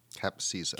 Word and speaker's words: Capcizza: It's spelled C-A-P-C-I-Z-Z-A. – Cap Caesar.